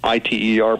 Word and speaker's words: ITER: I T E R, pronounced ITER, and they're going they're claiming it will produce ten times uh, the output of the energy that goes in.